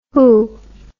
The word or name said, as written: whoo